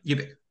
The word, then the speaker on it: Ubik: Is it pronounced Ubik or Ubik? – Yeah, Ubik. – Ubik.